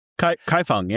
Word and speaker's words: Kaifeng: Kaifeng, yeah, beautiful.